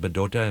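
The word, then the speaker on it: Bdote: The Dakota called this place Bedota, and it was sometimes spelled with an M, but mm-hmm. more recently with a B, because it sounds like Bedota, and Bedota literally means where the waters meet, and it's where this, that's where Fort Snelling was built.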